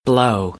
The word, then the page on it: blow: American English; oʊ